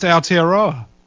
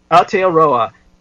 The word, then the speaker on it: Aotearoa: It's pronounced Aotearoa. – Aotearoa.